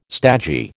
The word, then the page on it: stagey: American English